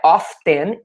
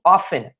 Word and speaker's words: often: I often, eu geralmente, I often, muito bem, não é often, e sim often.